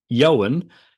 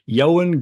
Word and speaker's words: Ioan: But yeah, so it is, yeah, Ioan, and I guess the U's in his surname are pronounced as I's, and if for the D's. Anyway, but that is the correct pronunciation. Ioan Gruffudd.